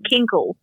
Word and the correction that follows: Kegel: Kegel. Kegel. It's Kegel. Kegel. – Okay, it's not kinkle.